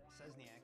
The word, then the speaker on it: Szczesniak: How do you say your last name again? – Szczesniak. – Szczesniak. – It's a Wesneski, I believe. It's a Polish name.